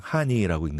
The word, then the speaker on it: 하늬: 근데 이제 읽을 때는 그냥 하니라고 읽는데 way you write it is 하니.